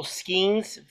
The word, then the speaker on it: Skenes: So the Pirates' number one pick, Paul Skenes, that's how you pronounce it.